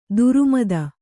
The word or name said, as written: ಧರ್ಮೇತ